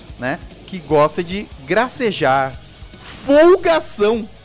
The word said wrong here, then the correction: folgazão: Que? Folgazão. – Folgazão, é isso. – Peraí, tá com Z ou com C cedilha? – Tá com Z, eu falei errado.